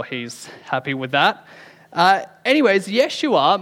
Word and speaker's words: Yeshua: Now, when we meet Jesus, we're all going to have to apologize for incorrectly pronouncing his name for the last 2,000 years. It's probably more pronounced something like Yeshua.